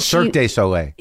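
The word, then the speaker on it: Cirque du Soleil: Circus de Soleil or whatever. – Circus Soleil. – Soleil. – Yeah. – And Cirque de Soleil.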